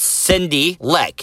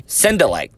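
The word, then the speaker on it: Sindylek: This first plug that I'm getting is, and I hope that I'm saying this name right, Justin Sindelek. Justin, S-I-N-D-Y-L-E-K. I think that's Cindy Leck. If you're going to like pronounce it phonetically, Sindelek. That's exactly what it is.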